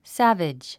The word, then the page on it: savage: sǽvidʒ; サヴェジ